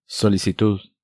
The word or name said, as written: Solicitud